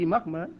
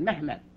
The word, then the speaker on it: Muhammad: Muckmud, pronouncing Muhammad Muckmud. You know, the Turks pronounce it Mehmet.